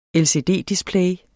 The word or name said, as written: LCD-display